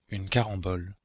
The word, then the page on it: carambole: Belgique, Brabant wallon; [yn kaʁɑ̃bɔl]